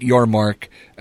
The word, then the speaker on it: Yormark: mispronouncing his name. It's Brett Yormark.